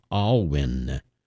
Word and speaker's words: Alwyn: By the way, the composer's name is pronounced Alwyn, William Alwyn.